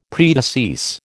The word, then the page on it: predecease: American English